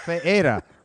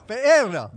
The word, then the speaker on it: Ferreira: No, not Ferreira. We can get Charlie Hood come here, and he'll really butcher it. Elo Ferreira. – Ferreira. – There you go, man. There you go. Roll that tongue.